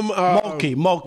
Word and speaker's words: Mulkey: The coach, I always mispronounce her name. What is it? Malarkey? – Kim Mulkey. I want to say Malarkey because I hear her talk.